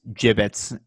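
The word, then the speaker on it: Jibbitz: Just want to clarify, it's pronounced gibbets, and they're a big part of Crocs' revenue going forward.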